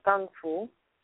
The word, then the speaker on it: Kung Fu: You see, we in the West have been guilty of bastardizing the true meaning of the term Kung Fu or Kung Fu, as Bruce Lee, being Cantonese, preferred to pronounce it.